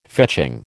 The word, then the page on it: fetching: American English